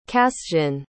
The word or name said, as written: cassian